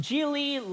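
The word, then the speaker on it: Gigli: Film that's pronounced Geely like really.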